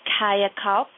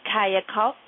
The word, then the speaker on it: kayakalp: I'm going to say it again and spell it. It is kayakalp, and it means rejuvenation. The spelling is kayakalpa, kayakalp. – Kayakalp. Mm-hmm. Kayakalp, everyone. So you have fresh start, which is your first word, and then kayakalp, which is your second word in Hindi, translation, rejuvenation.